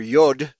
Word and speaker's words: Yod: Matthew 5:18, but in the Hebrew it is the Yotah or Yod, pronounced with a D.